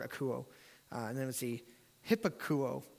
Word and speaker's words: akouo: The word obey in the Greek comes from a Greek word, I wrote down how to pronounce it from Chris, akouo, which is where we get the word acoustic from.